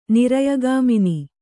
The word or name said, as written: ನಿರಯಗಾಮಿನಿ